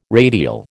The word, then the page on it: radial: American English